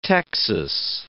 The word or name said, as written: texas